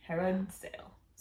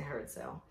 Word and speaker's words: Herod Sayle: Is that how you pronounce it? – Yeah, H-E-R-O-D-S-A-Y-L-E. Herod Sale. – So, I say Herod Sale.